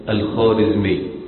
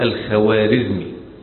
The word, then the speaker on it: خوارزمي: الخوارزمي تكتب بالواو، يعني بيسموها الخوارزمي، لكن عندما تقرأ لا تقرأ الخوارزمي، وهذا من الخطأ الكبير الذي يقع فيه كثير من الناس. يقول لك الدولة الخوارزمية، خوارزمشاة، هي خوارزمشاة والدولة الخوارزمية، تكتب ولكنها لا تنطق. تسمى هذه الواو في اللغة الفارسية بالواو المعدولة، تسمى في اللغة الفارسية بالواو المعدولة، أي تكتب ولا تنطق، زي في اللغة العربية أشياء تنطق ولا تكتب، مثل ماذا؟